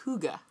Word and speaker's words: hygge: And it's pronounced hygge.